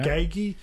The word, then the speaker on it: Gagi: It's like Japanese, like Miriam. You've got to shorten the vowel. So okay. Gagi, which is Miriam for the giant trevally.